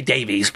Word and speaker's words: Davies: I'm pronouncing this Davis the English way, but we in America say Davies, but it's Dave, D-A-V-I-E-S. .com.